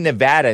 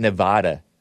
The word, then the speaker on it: Nevada: It's pronounced Nevada, not Nevada, by the way.